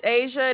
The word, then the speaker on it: Asia: My name is Asia. It is spelled A-S-I-A.